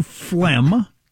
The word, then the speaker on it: phlegm: Number 2, phlegm. – I was thinking phlegm was up high. Yeah. Got a lot of silent letters in there.